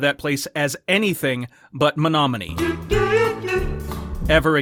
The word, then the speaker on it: Menominee: I won't be repeating my mistaken pronunciation, but I now understand that the town is pronounced Menominee.